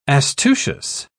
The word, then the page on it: astucious: British English